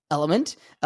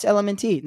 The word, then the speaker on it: LMNT: I say horrible marketing because it's spelled L-M-N-T and pronounced Element, which is actually really dope, but I started off like, oh, it's Element T. Like, no, bro, it's Element.